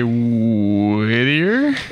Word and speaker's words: Whittier: That's the correct way to pronounce it. If you live in the city, you gotta say Whittier.